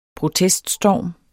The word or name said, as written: proteststorm